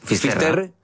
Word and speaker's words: Finisterre: Entonces, llegó a Finisterre, ¿se dice así? – Finisterre.